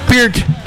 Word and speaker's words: Peart: So after Neil Peart died, Anyway, we said it wrong for the entire life. Peart. I was like, it was Peart, right? – Peart.